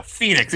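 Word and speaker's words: Fénix: No. Phoenix. He's a Mexican wrestler. It's spelled F-E-N-I-X.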